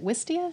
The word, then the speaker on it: Wistia: Is it Wistia? Is that how you pronounce? – Oh yeah. Yeah.